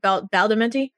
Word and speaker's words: Badalamenti: The soundtrack actually, because the soundtrack is like it's like one third Angelo Badalamenti. Am I saying his name right? – Badalamenti.